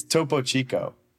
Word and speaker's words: Topo Chico: It's Topo Chico. – Topo Chico. – But that, and that that's different though, because Topo Chico's mineral water.